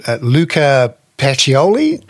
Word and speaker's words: Luca Pacioli: I couldn't help but notice that the father of accounting and bookkeeping, and I'll pronounce this badly, I'm afraid, Luca Pacioli? – Yeah, you pronounce it very well, yes.